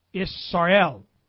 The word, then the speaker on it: Israel: By the way, how would you say the Prince of El? Israel. And that's what I believe the true pronunciation of Israel is.